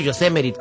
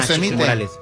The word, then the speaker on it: Yosemite: Yosemite o Yosemite, ¿cómo se llama? – Yosemite.